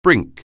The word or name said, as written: brink